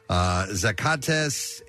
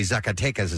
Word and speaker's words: Zacatecas: Zacatecas, a state in the with a population nearly 1.6 million. – Brutal. – Actually, Zacatecas is how it's pronounced, has been the site of a turf war between drug cartels and has been experiencing mounting violence in recent years.